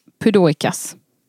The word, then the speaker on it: Pudoikas: Hans morfar var 68 år gammal, hette Stanislav Pudoikas. Uttalar jag det nu? Det är perfekt uttal.